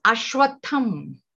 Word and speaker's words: ashvatha: is ashvatham. Ashvatha word is very peculiar. Shva means tomorrow. If you break that word, it has got three syllables. There is a, there is shva, and there is tha.